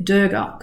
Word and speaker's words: Durga: And if you're wondering why I say Durga, it's because I learned about this in India, and my teacher said it's very important to pronounce Durga's name properly. So I don't say, normally I would have said Durga because it's spelled D-U-R-G-A, but I was told, give her the respect that she's due, goddess is due, and pronounce her name properly, Durga.